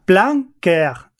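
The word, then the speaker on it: Plan Cœur: ¿Cómo se pronunciaría esto, que está en el guión, abajo? – Plan Coeur, que sería más o menos como plan corazón.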